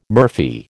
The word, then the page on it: murphy: American English